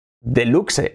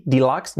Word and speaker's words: deluxe: Fijaos que aquí se escribe con E, pero la pronunciación es casi una mezcla entre I y E. Casi una E, prácticamente. D, E. No es D, deluxe, no, D, deluxe.